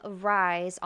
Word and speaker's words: Ryz: And today we have creator of Rise Online Magazine, and that's spelled R-Y-Z, and it's an acronym for Reach Your Zenith.